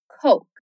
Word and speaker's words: Coke: Ou simplesmente Coke. A bebida mais famosa do mundo. Apesar de em muitos lugares dos Estados Unidos a Coca ser chamada de Coke, esse é só um apelido. Alguns lugares usam Coke.